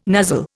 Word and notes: nuzzle: Indian

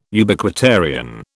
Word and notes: Ubiquitarian: British English

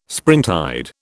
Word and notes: springtide: British English

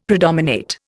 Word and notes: predominate: Indian

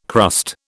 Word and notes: crust: British English

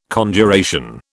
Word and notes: Conjuration: British English

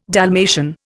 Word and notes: Dalmatian: Indian

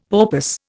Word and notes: porpoise: Indian

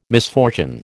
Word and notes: misfortune: American English

